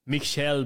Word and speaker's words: Michael: Um Michel Ballack. I don't know. It's like Michael, but I try to pronounce it the German way.